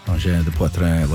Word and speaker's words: engins de poitrine: And then I got a text from our friend Louise, who said the last syllable of engins de poitrine is pronounced like in because of the E at the end. Engins de poitrine, like in. Okay, I think I got it.